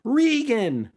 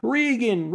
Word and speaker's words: Reagan: Others shout, Regan! Not everybody could pronounce his name right at this time. Reagan, Reagan, they shout.